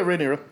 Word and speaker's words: Rhaenyra: Yeah, Rhaenyra.